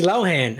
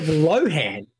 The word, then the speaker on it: Lohan: Instead of Lohan, as pretty much everyone's been saying it, Lindsay pronounced her surname as Lohan. in her thread.